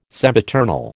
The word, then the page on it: sempiternal: American English